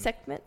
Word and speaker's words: Sekhmet: Sekhmet. – Yeah. So Sekhmet's first. – Which one's Sekhmet? – She's the one lying down.